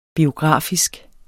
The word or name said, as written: biografisk